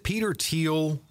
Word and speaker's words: Thiel: You mentioned the feeling they had about Roth, and probably what exacerbated that feeling is it Peter Thiel? Is that how you pronounce it? – Yes.